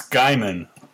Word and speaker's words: Guymon: It's Guymon, almost like a M-U-N sound.